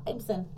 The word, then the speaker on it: Ibsen: Ibsen? – Yeah, Ibsen.